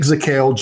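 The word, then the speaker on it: Zakelj: So let's go with Nick Zakel. Jay is silent.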